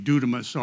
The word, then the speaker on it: dunamis: It's the word we call, I think pretty close pronunciation would be dunamis, or where we get the word dynamite from.